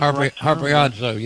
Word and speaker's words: harpazo: the harpazo, the pulling away. Is that correct? Is that harpazo? Yes, the snatching.